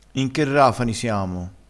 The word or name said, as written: rafani